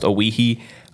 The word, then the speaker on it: Owyhee: They've got this upcoming week off before they host Owyhee, as I'm pretty sure it's O W Y, but I'm pretty sure it's pronounced Owyhee. Uh, Saturday, September 17th, here in Carson City.